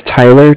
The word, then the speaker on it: Tyler: My name is Tyler, T-Y-L-E-R. Okay. And I'm calling with Cardiff. It's spelled C-A-R-D-I-F-F, as in Frank Frank.